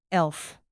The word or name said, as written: elf